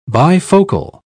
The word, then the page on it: bifocal: British English